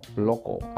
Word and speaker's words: local: Tenemos la otra que se llama local, loco, algo local. Entonces escribe L-O-C-A-L, loco.